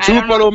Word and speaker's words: Tupelo: Tupelo, Mississippi, the birthplace of Elvis.